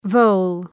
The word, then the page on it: vole: vəʋl